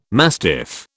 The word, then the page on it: mastiff: British English